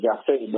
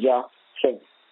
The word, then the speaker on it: Jahseh: You all pronounce it in American Jah-Sheh, but his name is Jah-Sheh.